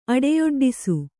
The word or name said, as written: ಅಡೆಯೊಡ್ಡಿಸು